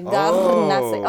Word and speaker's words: Garnace: Garnace. I can never say my last name right. You gotta roll the R.